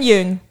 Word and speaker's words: Yeun: Steven, en hoe spreek je zijn achternaam uit? – Yoon? – Yoon? – Nee, Yoon. – Steven Yeun.